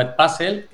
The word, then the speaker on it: Edpuzzle: Nosotros decimos Edpuzzle, que es, digamos, en inglés, pero se puede pronunciar como queráis.